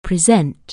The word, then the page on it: present: anglais